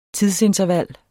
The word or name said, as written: tidsinterval